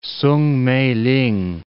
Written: Soong Mei-ling

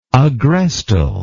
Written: agrestal

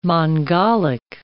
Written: Mongolic

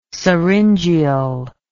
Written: syringeal

